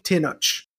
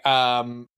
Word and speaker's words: Tenoch: I've been saying Tenoch with the ch sound. I think that's how they say it in the movie too.